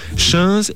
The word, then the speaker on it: chainse: Alors les tuniques, chins et bliots. Chins, ça s'écrit C-H-A-I-N-S-E et bliot, B-L-I-A-U-D. Depuis le Xe siècle, les deux pièces principales de l'habillement des hommes et des femmes sont le chinze et le bliot.